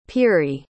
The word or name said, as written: Peery